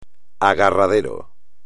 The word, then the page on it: agarradero: espagnol